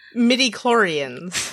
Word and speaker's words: midichlorians: Made from the metachlorines. I know I said that wrong. Wait, did I say it wrong again? – Midichlorians.